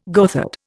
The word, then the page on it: goatherd: Indian